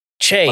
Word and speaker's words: Che: The SNL skits with Michael Che and Che. – What's his name? – Che. It's a black guy. You got to pronounce it right. It's Black History Month.